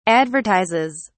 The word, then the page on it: advertises: British English